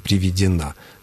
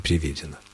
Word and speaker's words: приведена: Правильное ударение, причем с буквой «ё» в третьем слоге в случае мужского рода «приведен», ну, а для женского рода «приведена», но никак не «приведена».